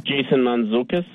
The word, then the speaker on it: Mantzoukas: Jason Mantzoukas.